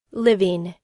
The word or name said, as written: living